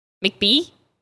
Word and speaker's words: McBee: McBee? – It's not how you say it. It's McBee. Damn, California.